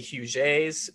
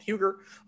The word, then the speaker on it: Huger: where you see Huguet's name. That's how it's supposed to be pronounced. It's a French Huguenot family, but a lot of people call it Huger.